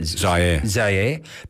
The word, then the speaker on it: Zaire: Zaire.